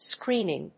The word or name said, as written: screening